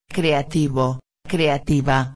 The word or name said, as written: creativo-a